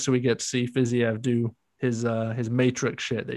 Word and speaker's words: Fiziev: Got your boy, Rafael Fiziev. I learned it this week that it's pronounced Fiziev, Fiziev, not Fiziev. Yeah. Everyone calls him Fiziev. Fiziev. Apparently, it's Fiziev.